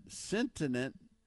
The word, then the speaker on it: sentient: Fisher sent sentient, sentient individuals who feel pain. How do you pronounce that? – Sentient. Sentient, sentient. – individuals who feel pain.